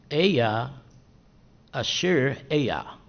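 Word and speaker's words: Ehyeh Asher Ehyeh: In Hebrew, this would be pronounced like this. Eya Asher Eya. Eya Asher Eya. It's E-H-Y-E-H, and then Asher, A-S-H-E-R, and then Eya again, E-H-Y-E-H.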